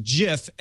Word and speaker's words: GIF: It is a soft G pronounced GIF.